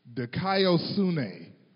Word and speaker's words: dikaiosune: There's this crazy word that does not even look like the way you're supposed to pronounce it. It is dikaiosune. Would you ever pronounce that word that way?